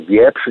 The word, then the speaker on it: wieprz: Ale kiedy mówię wieprz, nie słyszę ani czystego R, ani Z.